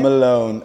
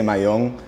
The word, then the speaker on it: Malone: Malone. People say it differently. Some people say Malin. In Spanish, I'm Cuban American, so we say Mayon.